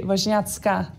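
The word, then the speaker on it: Wozniacki: Fordi vi har nemlig ingen ringer end Karoline Vosni Aki på besøg. Velkommen til. Actually, the I in the end would be an A, and it would be Vosniatska. So that would be the proper pronunciation.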